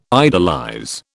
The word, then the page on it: idolize: British English